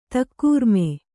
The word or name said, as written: ತಕ್ಕೂರ್ಮೆ